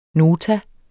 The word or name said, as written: nota